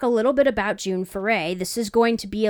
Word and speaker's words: Foray: And we wonder if maybe it was pronounced Foray, but spelled differently. It's spelled F-O-R-E-R. But in interviews, she said that she was born June Foray.